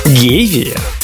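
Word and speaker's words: гевея: Гевия? Дерево гевия? – Гевия. Именно на «е» ударение. Я специально нашла гевия или хевия.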